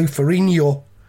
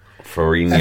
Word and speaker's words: Ferrigno: But I mean, I've just realised as well that I've been mispronouncing his name for this entire episode. It's Lou Ferrigno. – Ferrigno. – F-E-R-R-I-G-N-O.